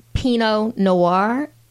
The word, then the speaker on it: Pinot Noir: And those are going to be more like your Pinot Noir. Did I say that right, Alicia? – You did. Okay.